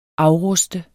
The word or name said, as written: AFRUSTEDE